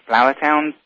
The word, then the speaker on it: Flourtown: Jerry's currently the director of development for Whitemarsh House, which is a personal care facility located in Flourtown, Pennsylvania.